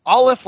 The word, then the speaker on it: Olliffe: Yes. Olive like olive, but with F sounds.